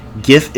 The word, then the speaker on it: GIF: He says, GIF is pronounced gift without the T.